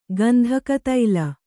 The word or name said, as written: ಗಂಧಕತೈಲ